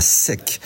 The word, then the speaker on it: Setques: Ce matin, j'étais à sec. Voilà, à sec, ouais. Ça s'écrit S-E-T-Q-U-E-S, mais le gag, c'est que ça s'appelle sec et que c'est inondé, voilà.